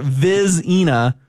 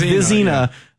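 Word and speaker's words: Vizzina: I went to the Clemson pronunciation guide. Hometown kid, we can't do him like this. It is Christopher Vizina. – Yeah, Vizina. So it's Vizina.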